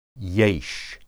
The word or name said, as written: yesh